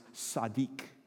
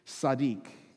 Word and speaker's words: tzaddik: And the Hebrew word for a righteous man is pronounced tzaddik, tzaddik.